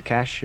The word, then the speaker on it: cache: Am I saying that right? Cache is cache. – Yeah, that's right. Yeah, okay. I think that's, some people say cache, but I think that might be a totally different word.